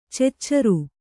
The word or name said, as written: ಚುಚುರು